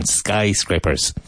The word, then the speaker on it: skyscrapers: Careful of the pronunciation. Skyscrapers. It's famous for its skyscrapers. Grape, crepe. It's famous for its skyscrapers. That long A sound is essential.